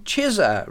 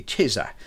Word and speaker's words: Chisa: a 1967 release in the US on Chizza Records. I think that's how it's pronounced, C-H-I-S-A, Chizza.